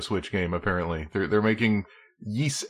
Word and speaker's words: Ys: I learned in this Nintendo Direct that I have been pronouncing Yees wrong my entire life. Because I've been pronouncing it Wise.